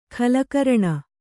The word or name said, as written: ಖಲಕರಣ